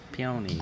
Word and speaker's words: peonies: How do you say that? Peonies? – Peonies. – I think you said it right the first time. Peonies.